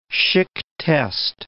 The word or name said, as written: Schick test